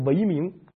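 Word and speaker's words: 嵬名: 们家在唐朝姓李，在这个宋朝姓赵，本姓据说是拓跋，现在全不要了，也不拓跋，也不李，也不赵了，改姓维明。为什么姓维明呢？有一种说法呢，说维明这个姓啊，就是北魏皇族的元氏，然后把这个姓音译过来，翻译成党项语，党项语就这么发音，元它发音就是维明。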